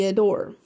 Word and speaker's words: Adour: and that's not A-D-O-R-E, that's A-D-O-U-R, and to me, it looks like a dower, but it's saying it's pronounced ADOUR,